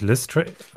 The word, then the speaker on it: Lestrange: Äh, der, der Chat ist sich übrigens ganz sicher, es heißt Lestrange. Es wird äh, okay. Englisch ausgesprochen. – Äh, la, la, last, nee, Lestrange.